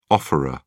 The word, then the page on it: offeror: British English